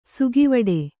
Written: ಸಾಗುವಾಡ

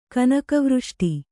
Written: ಕನಕವೃಷ್ಟಿ

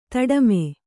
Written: ದುಡುಮಿ

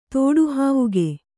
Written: ತೋಡುಹಾವುಗೆ

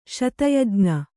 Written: ಶತಯಜ್ಞ

